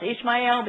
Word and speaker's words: Ishmael: It should be pronounced Ishmael, but you'll hear me say Ishmael. So I might slip back and forth. If Jeff Griffiths were here, it would be Ishmael. He would say it right.